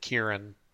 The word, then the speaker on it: Ciaran: He actually says you pronounce it Kieran.